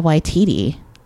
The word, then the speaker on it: Waititi: by Taika Waititi. Oh my god, I said his name right. – you said it right.